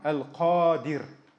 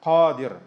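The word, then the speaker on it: Аль-Кадир (القادر): Первое имя из его имен – Аль-Кадир. Кадир – ударение, то есть после «каф» идет «алиф», «мед». Да? Кадир, Аль-Кадир.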